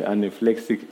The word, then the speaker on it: anaphylactic: But you know, when you, they call it you call it anaflexic attack. Is it how you pronounce it? Yeah, anaphylactic attack.